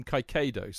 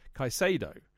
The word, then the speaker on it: Caicedo: I called him Kaikado, so I pronounced it with a noisy C rather than a silent C, which would be Kaisado.